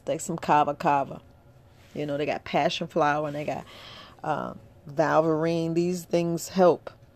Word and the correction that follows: Valerian: Could be saying it wrong. Valine. It's Valerian.